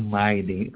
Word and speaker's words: Maylie: No, Maylie. Como Miley Cyrus.